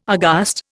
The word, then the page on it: aghast: Indian